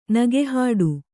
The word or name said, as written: ನಗೆಹಾಡು